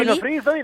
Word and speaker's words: Pino Frisoli: Fammi citare anche Pino Frisoli, si dice, si pronuncia così, l'accento è giusto, Pino Frisoli? – Pino Frisoli, l'accento è giusto, Pino è un accento sdrucciolo, e è stato un libro scritto